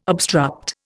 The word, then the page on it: obstruct: Indian